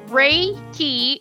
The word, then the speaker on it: Reiki: So Reiki, pronounced Reiki, R-A-Y-K-E-Y, that's how it's pronounced, is a Japanese technique for stress reduction and relaxation that also promotes healing.